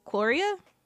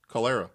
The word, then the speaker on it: cholera: How do you pronounce this? Chloria? – Cholera.